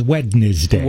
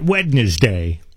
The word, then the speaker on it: Wednesday: But Wednesday prevails in its three-syllable thing. Like it can't just be W-E-N-S-D-A-Y.